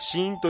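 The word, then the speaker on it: 死印: えー、死因という、死の印と書いて死因という、えー、ホラーゲームがリリースされるみたいで、これちょっと気になりますね。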